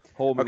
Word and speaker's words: Holman: and I think it's Holman Wiggins. Help me out with the name. I'm sorry. – Yes, Holman.